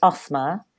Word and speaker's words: Asma: It's very, very, so my name is pronounced Asma. It's not complex. And actually, in terms of the sounds, us and mer, they are sounds that are in the English language.